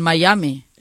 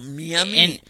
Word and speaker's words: Miami: No, Miami. – En español es Miami, castellanizalo. – No. – El wifi. – No, el M con el I se pronuncia Mai.